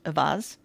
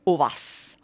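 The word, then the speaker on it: Auxvasse: spelled Ox-Vassé, pronounced Avaz. In French, it would be Ovas.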